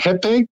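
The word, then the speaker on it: rapé: Jepe? Jepe? – Yeah, they spell it R-A-P-E, but it's pronounced jepe.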